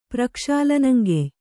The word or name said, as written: ಪ್ರಕ್ಷಾಲನಂಗೆಯ್